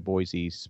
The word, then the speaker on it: Boise: But there was complaints on our Facebook page because they're from Idaho, and they said the people from Boise, Idaho, I'm trying to say it right because there's a correct way to say it. Boise. You got to say it the right way, or you get in trouble.